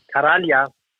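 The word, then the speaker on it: Karjala: Der richtige, der große Kader mit Anführungszeichen, der spielt beim, wenn ich es wieder falsch aussprechen, sag's. – Diese Biermarke, Karalia. – Oder? – Karalia. – Karalia Cup, so.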